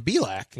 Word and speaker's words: Belak: I'd rather sit here and eat these Cheez-Its and talk about something else other than Brandon Belak. – Well, he rhymes with crack, okay?